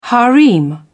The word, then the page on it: harem: British English